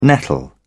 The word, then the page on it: nettle: British English